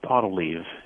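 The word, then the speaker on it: Autoliv: Is it AutoLive or AutoLive? – It's pronounced AutoLive. It's AutoLive.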